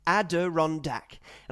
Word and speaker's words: Adirondack: Ad, as in Federer's lead in a juice game. As in the sound makes when he's asked the week's topic. Ron, as in big. Dak, as in the player Prescott in that American sport. Adirondack.